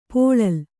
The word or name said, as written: ಪುಳಿಲ್